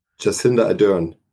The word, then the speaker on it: Jacinda Ardern: Jacinda Ardern.